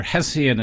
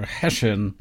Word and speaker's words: Hessian: Yeah, and there are two pronunciations of Hessian. We do say either Hessian in Britain or Hessian, but we can use either one today.